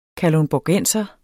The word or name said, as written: kalundborgenser